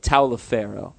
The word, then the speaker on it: Taliaferro: That is how you pronounce it, by the way. Taliaferro.